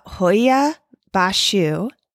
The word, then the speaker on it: Hoia Baciu: I looked up how to say it. Hoia Bashu Forest.